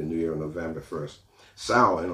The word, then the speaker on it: Samhain: Actually, it's pronounced Samhain, okay? You've probably seen the word Samhain, S-A-M-H-A-I-N, but it's pronounced Samhain.